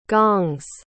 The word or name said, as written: gongs